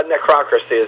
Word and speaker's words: Necrocracy: How do you pronounce it? – Necrocracy is how. – Okay, that's how I was like, is it necrocracy? – Yeah, like democracy.